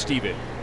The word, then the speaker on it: Stephan: Last name spelled S-T-E-P-H-A-N, but pronounced Steven.